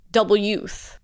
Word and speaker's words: W Youth: Which, if you read the press release, is to be pronounced Double Youth. Get it? – I get it. But that's just kind of hard because that's not how it's spelled. But whatever.